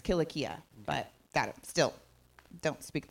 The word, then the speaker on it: Cilicia: Cyrenians and Alexandrians and the Cilicia. That's what I when I read Cilicia.